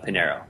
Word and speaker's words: Pinheiro: No, totally fine. It's Pinheiro. – Yeah, there we go. And it's good to know that that's the way they pronounce it, too, because that's the way I've always said it.